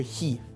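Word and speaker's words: «хи» (χ): Есть такая буковка «хи» в греческом алфавите, она читается как «х», и в нашем случае она является первой буквой, ну и русского, и в каком-то смысле греческого слова «хроматическое число».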